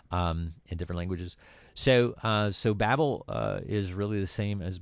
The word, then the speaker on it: Babel: of Babel is actually the Hebrew word is Babel, which we pronounce that V as a B.